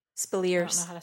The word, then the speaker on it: Speleers: Ed Speleers, is that his, how you say his name? Spielers? Spellers? – Spilliers. – Spilliers. I don't know how to say it.